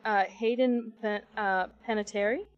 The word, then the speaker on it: Hayden Panettiere: I never say her name right. Is it Hayden Panettiere? – Yeah.